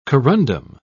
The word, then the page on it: corundum: British English